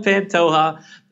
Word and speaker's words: Pantoja: So you'll be able to spell that last name, but it's spelled like a jalapeno. The, the J is soft, okay? So Antonio Pantoja.